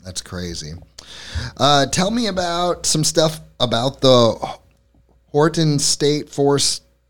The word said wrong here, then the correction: Wharton: Wharton? – Am I saying it right? – Wharton, yeah.